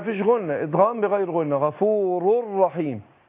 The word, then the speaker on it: غفور رحيم: ويبقى حقيقة الإدغام هنا ان نون التنوين هي اللي أدغمت في الراء، فأنا بنطق رأين مع بعض مع كون اللي أدغمنا نون التنوين في الراء بقول غفور رر يبقى أقول غفور رحيم غفور رحيم هكذا، يبقى أقول والله غفور رحيم.